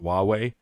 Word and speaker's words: Huawei: If you look at the name, it actually looks like Hawaii, mm-hmm, but longer, but it's pronounced Huawei.